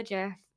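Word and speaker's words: Djerf: So, I don't know, like, if you know this, but, like, Matilda Jerf. Is that how you say her name? – Yeah. Like, Jerf Avenue.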